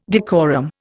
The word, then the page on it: decorum: Indian